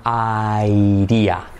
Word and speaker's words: idea: Es para el truco para aquí, fíjate, no se dice idea, sino airía. Airía, la D como una R suave española, airía, ay, como que me quemo, airía.